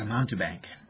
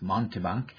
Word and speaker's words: mountebank: Now, if you're anything like me, you've got to be wondering, what's a mountebank? I pronounced it a little wrong. It's mountebank.